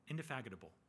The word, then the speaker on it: indefatigable: Indefatigable. That's how you say it. Indefatigable patience of God. I said it right.